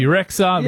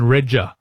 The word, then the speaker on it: Rexha: Pronounce it BB Rexha, which is what I think she does in her American world as well. But in Albania, her actual name is Bebe, which means B, and Regja.